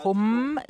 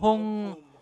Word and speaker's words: Hung: Nicht Hum, sondern Hung. Der Mund schließt sich nicht. Om. Bei Om verschließt sich der Mund. Das ist ein echtes M. Om, A, Hung. Oder Hung. Hung. Das ist die richtige Aussprache.